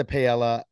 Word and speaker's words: paella: First things first, do not eat the paella at the Eagle. Did I pronounce that right? Is it paella? – Paella.